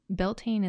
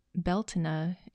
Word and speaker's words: Beltane: Now, you may hear different pronunciations of Beltane, and I've learned that the pronunciation is Beltane in Scottish Gaelic and Bealtaine in Irish Gaelic. So both are right.